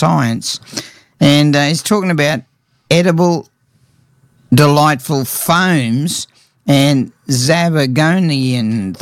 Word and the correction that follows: zabaglione: That would be Zabaglione. – Yep. You there, Jeremy? – Oh. – Zabaglione.